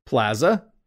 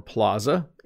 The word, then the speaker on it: plaza: Plaza or plaza. Interesting, isn't it? We have two pronunciations of this word. In North America, a plaza is usually used to talk about a shopping mall like the one in the first picture up here. So often there are shopping plazas. Okay, so you might go to the plaza to buy some things. You might go to the shopping plaza to buy things. We also sometimes say plaza. Notice how I pronounced it a little differently.